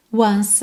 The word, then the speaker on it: once: Just to touch you once again. Once, Como o E final de once não é pronunciado, a gente já junta no again. Once again.